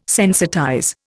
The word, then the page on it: sensitize: Indian